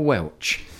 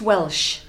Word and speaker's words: Welch: Do you prefer a Welsh or Welch? – Well, it's Welsh. It sounds SH, but it's spelt CH.